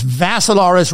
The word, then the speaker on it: Vassilaris: The last name is Vassilaris, rhymes with Asilaris, but you add a V.